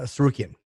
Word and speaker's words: Tsarukyan: He he he lost uh, a uh, decision to Arman to scarf to, to, how do you pronounce no, it? No, it's uh, uh, uh Tsarukyan.